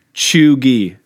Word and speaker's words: cheugy: Pronounced Chugi, spelled C-H-E-U-G-Y.